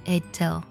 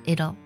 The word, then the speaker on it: it'll: 那么连起来就是 it'll it'll it'll。这种发音呢，其实在英式的发音当中是非常多见的，而在美式的发音当中啊，经常呢会将 it will 的缩写呢读作 it'll。